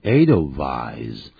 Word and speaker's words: Edelweiss: W-I-T-H-E-R, W-I-T-H-E-R, wither. – 我在这里我特别看的这个字叫 Edelweiss，那个 W 那它是不念成 Y，而是念成 Wedding Edelweiss。 – Yeah， that's the German pronunciation。